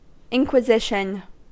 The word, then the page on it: inquisition: American English